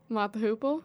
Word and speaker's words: Mott the Hoople: Mott the Hoople.